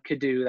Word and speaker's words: Kiddoo: So you can look me up, Adam Kiddoo. That's my username. And that's spelled K-I-D-D-O-O.